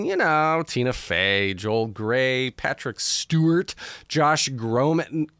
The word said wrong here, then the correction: Groban: Josh Groban and Lin-Manuel Miranda, just to name drop a few.